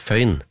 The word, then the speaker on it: Foyn: Og når jeg sier da Sven Foyn, så er det mange som kommer til å si fra Tønsberg, så sier de, ja, men du uttales Føyn. Ja vel, sier jeg da, hvis han på død og liv skulle ha denne øen, så får han heller stave det med ø.